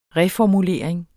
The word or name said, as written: reformulering